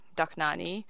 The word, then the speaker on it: Dohnányi: A concert on Beethoven and friends, concert on the Hungarian tradition, including Liszt, Bartok, Kodály, Dohnányi, and then back to Dvořák.